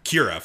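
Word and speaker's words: Qi'ra: Quara, the love interest of Han Solo in the movie. – Kira, thank you.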